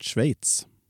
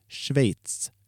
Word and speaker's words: Schweiz: Att du kunde uttala landet Schweiz. Så får du säga efter mig 3 gånger nu. Schweiz. Schweiz. – Schweiz.